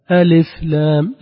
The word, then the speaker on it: ألف لام: ألف لام ألف عفوا كسرة اللام في ألف لابد أن تأتي تامة. ألف لام لا تقل ألف. ألف هنا نكون أخذنا منها شيئا، سرقنا الحركة. نقول ألف ألف لام ألف لام هكذا النطق الصحيح.